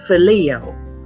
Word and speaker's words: philia: Now you can pronounce agape as agapeo, agapeo, and you can pronounce philia as phileo. Okay, either or. So, Peter used the word philia or phileo each time he answered Jesus.